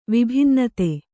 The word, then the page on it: ವಿಭಿನ್ನತೆ: vibhinnate